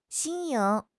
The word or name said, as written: 新颖